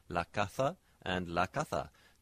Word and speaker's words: caza: This is known in Spanish as distinción, and in some places, the word casa, C-A-S-A, which means the house, it can be contrasted with the word casa. Well, I'm saying that with a very English pronunciation, C-A-Z-A. So casa, house, with an S, and caza for the hunt, C-A-Z-A.